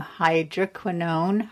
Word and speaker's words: hydroquinone: So I use some face cream that might have some hydroquinone.